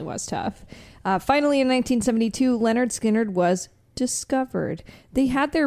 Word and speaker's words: Lynyrd Skynyrd: He signed them to Sounds of the South and produced their first record, which is called, pronounced Lynyrd Skynyrd, which was released on August 13th, 1973.